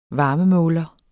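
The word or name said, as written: varmemåler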